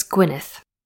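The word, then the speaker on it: Gwynedd: Oh, how do you pronounce that? Very quick, pronounce names dad calm. Search. It's Gwynneth. The Wynns were descendants of the kings of Gwynneth, and they were one of the most significant families of North Wales during the Tudor and Stuart periods.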